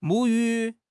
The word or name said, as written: 母语